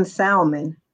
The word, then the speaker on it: salmon: salmon, like if you pronounce the L, he can't date you.